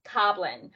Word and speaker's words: Coblin: Coblin. – It's goblin with a C.